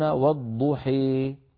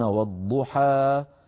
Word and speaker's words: والضحى: فالألف في كلمة والضحي عند من قرأ بالإمالة، ليس طبعا ليس عند كل القراء، عند من قرأ بالإمالة الكبرى كحمزة والكسائي وخلف البزار يقولون والضحي هكذا ممالة، وهكذا رواها وهكذا تنطقها كم وكم من قبائل العرب. قريش ما كانت تفعل ذلك، قبيلة قريش كانوا أهل فتح يقولون والضحى والليل إذا سجى.